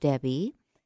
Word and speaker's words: Debby: Write to Coach Debbie, and Debbie is spelled D-E-B-B-Y.